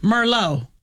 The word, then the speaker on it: Merlot: Yeah, remember Merlot is, and that T is silent the T on is the silent. End of Merlot.